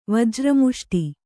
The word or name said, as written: ವಜ್ರಮುಷ್ಟಿ